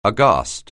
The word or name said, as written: aghast